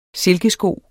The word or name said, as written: silkesko